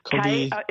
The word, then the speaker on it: Kobi: Kobe Yamada, is it? Or yes, Kobe... it's spelled differently. He is Canadian, but Japanese surname. Mm-hmm. K-O-B-I, and his surname is Y-A-M-A-D-A.